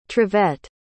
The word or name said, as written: trivette